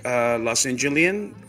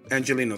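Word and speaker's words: Angeleno: He's a native Los Angelian. How do you say it? – Angelino.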